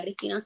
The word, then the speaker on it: Cristina: Eh, mi nombre es Carla Cristina, se escribe con C, sin H.